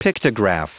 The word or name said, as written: pictograph